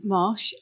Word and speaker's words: Marsh: S-A-R-A-H. So there's an H in Sarah. – Right. – And then Marsh, M-A-R-S-H, hyphen C-O-L-L-I-N-G-S.